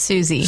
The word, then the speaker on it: Siouxsie: Susie is spelled S-I-O-U-X-S-I-E. – I thought Banshees was going to be the harder one here, but no, it's Susie.